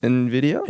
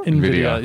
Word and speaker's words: NVIDIA: I think it's pronounced NVIDIA. – NVIDIA.